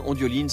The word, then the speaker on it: Ondioline: Ondioline, c'est comme ça se prononce, c'est très simple.